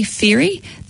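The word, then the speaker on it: Fairey: If you have a look on Twitter and Facebook and search for Julie Ferry, that's spelled F for Fred, A-I-R-E-Y.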